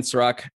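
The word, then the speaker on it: Serak: It's a weekly review, but easy way to search is type my name in Serac, S-E-R-A-K. I know, unfortunately, not spelled like the liquor, but I promise you, I taste way better.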